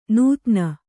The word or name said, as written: ನೂತ್ನ